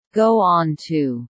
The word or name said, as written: go on to